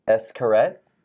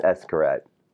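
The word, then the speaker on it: Xcaret: It starts with an X. Now, she said, now I want to take my kids to the parks. I do believe it's Escarette.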